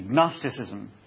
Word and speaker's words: Gnosticism: In the first few centuries, there was a doctrine called Gnosticism, spelled with a G, Gnosticism.